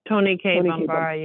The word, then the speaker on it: Bambara: Bambara? – Toni K. Bambara, yeah.